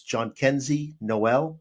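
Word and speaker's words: Jhonkensy Noel: And so I'm going to, you know, I checked Baseball Reference because I want to make sure I try to pronounce his first name correctly, but I believe it's John Kenzie Noel. His first name is John Kenzie, and last name Noel, N-O-E-L.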